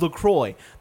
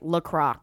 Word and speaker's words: LaCroix: 2 ounces of LaCroix. That is like a fourth of a can. – Say it right, LaCroix.